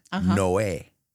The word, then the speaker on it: Noé: And Noah and the Ark, Noé.